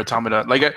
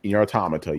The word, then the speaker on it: Automata: So yeah, I've just been playing Nier Automata. And I pronounced it not right. – Yeah, you got it. Nier Automata, you got it. Remember, cool. So play on the word automaton.